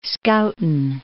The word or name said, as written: Schouten Islands